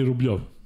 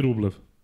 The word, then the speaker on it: Rublev: Na ATP-u imaš stranice igrača, gde oni sami izgovaraju svoje ime. I on kaže... Andrej Rublev.